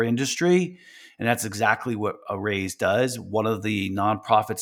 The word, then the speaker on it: Araize: It's Arayz Fast Fund Online. It's spelled A-R-A-I-Z-E.